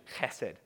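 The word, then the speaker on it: chesed (חסד): Now, it's actually not hesed, it's actually chesed.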